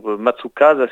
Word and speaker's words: Matsukaze: Matsukaze, si on le prononce à la française.